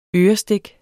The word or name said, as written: ørestik